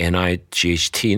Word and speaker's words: knight: OK，这个最后那个字啊，就是 K N I G H T，那字就是骑士的意思。 K 不要发音，后面念 night 就好了哈。